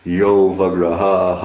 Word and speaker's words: avagraha: There's really the word see is avagraha, but because of Sanskrit rules, the A is not pronounced. It's you do it like one word, yo vagraha aham. But there's actually the three words is ya, avagraha, and then aham.